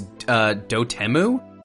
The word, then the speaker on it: Dotemu: And I finally learned how to pronounce that developer's name. Apparently it's Dotemu. – Wow. I was saying Dotemu. – Yeah. – I thought it was Dotemu, but in the showcase they said Dotemu.